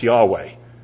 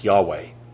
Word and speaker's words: Yahweh: And it should be pronounced Yahweh.